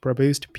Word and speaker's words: Praboost: And you can reach out to me through Instagram and Twitter at Praboost. I know it's a weird name, but it's pronounced Praboost, P-R-A-B-O-O-S-T.